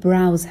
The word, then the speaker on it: browse: Browsing social media، به تلفظ browse دقت کنین. معمولا اشتباه خونده میشه، ولی browse هستش.